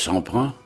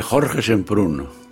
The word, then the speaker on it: Semprún: Je commencerai par le dire en français, c'est Georges Semprin, et en espagnol c'est Jorge Semprún.